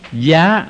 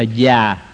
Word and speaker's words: giá: Giá, mình đọc là giá, nhưng mà có chỗ đọc là già.